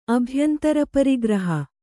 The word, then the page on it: ಅಭ್ಯಂತರಪರಿಗ್ರಹ: kannada